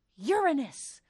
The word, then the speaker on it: Uranus: For the last time, it is pronounced Uranus.